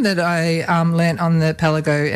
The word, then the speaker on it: kpalango: So, yeah, so it's spelled actually K-P-A-L-A-N-G-O, which you might think sounds like Kapanlago, right? – It's like Pialajo down in Canberra. – But being a Ghanaian word in the language of Ga, which is the language it's in, it's pronounced Pelago.